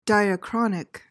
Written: diachronic

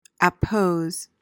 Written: appose

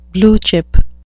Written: blue chip

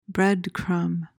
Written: breadcrumb

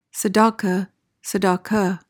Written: tzedakah

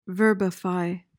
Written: verbify

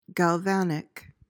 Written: galvanic

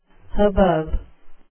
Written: hubbub

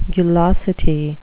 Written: gulosity

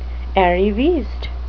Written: arriviste

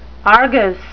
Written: argus

